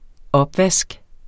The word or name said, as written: opvask